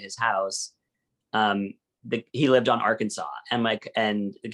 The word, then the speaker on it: Arkansas: And kind of fun that there's an Arkansas. Am I saying that right? – Yeah.